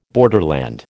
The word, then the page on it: borderland: American English